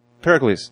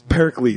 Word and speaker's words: Pericles: Perseles? Perleces? – Persephone. – Why the fuck can't we get that name? – Pericles. – Pericles.